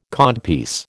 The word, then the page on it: codpiece: American English